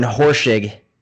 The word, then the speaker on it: Horschig: Dr. Aaron Horschig, we are live. Did I say that right? – You said it right.